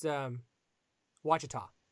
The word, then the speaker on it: Ouachita: It's spelled, for those who don't know, it's spelled like O-U-A-C-H-I-T-A. To embarrass myself, I thought it was Wachita. No, it's Wachita.